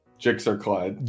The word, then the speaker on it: GSXRCLYD: Jigsaw Clyde. – Jigsaw Clyde. – Wow. – Can you spell that for us? – G-S-X-R-C-L-Y-D.